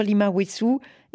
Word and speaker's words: Lima Wessou: C'est Youssoundour Lima Wessou. Est-ce que je l'ai bien prononcé, Lima Wessou? C'est Wessou ou Wessou? – Super bien, c'est comme ça, super.